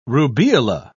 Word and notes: rubeola: British English

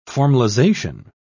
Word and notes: formalisation: British English

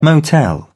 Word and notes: motel: British English

